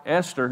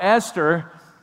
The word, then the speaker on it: Esther: And when we think about what it must have been like for Esther, now I'm almost saying it wrong, for Esther,